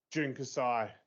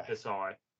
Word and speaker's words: Jun Kasai: I don't know if that was from night one, where it was the tag with Despy and June Kazasi. I've completely butchered that. I apologize. – June Kasai, yeah. – Kasai.